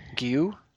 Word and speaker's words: Guilloux: Guillou, very good.